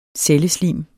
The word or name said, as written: celleslim